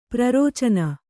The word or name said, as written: ಪ್ರರೋಚನೆ